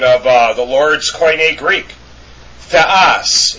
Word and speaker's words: Theos: It's not Theos. Theos is gibberish. Theos is how you pronounce it. Theos. Not Theos. Not Theos. Not Theos. Theos. That is the correct pronunciation of that form.